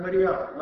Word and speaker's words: Мариам: И в евангельских чтениях иногда имя Девы Марии прочитывается как Мариам на церковнославянском языке. Это более правильное произношение этого имени.